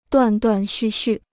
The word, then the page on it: 断断续续: duàn duàn xù xù